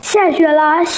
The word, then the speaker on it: 了: 下雪了，下雪了。 – 非常好，注意读准了拉的轻声。